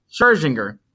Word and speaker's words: Scherzinger: How do you say her name, nigga? Scherzinger.